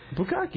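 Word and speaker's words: Bukkake (ぶっかけ): Was it a Bukkake or something like that? – Is that how that word's pronounced? – Bukkake, yeah.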